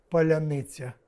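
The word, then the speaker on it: паляниця: На прощание, в смысле под конец, скажите, как правильно произносить слово «поляныця»? – Поляныця.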